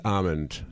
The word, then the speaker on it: almond: There's an L in a lot almond of words that are silent. You know? – Yeah, but not this one.